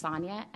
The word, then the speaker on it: Sonja: It's Sonja, S-O-N-J-A.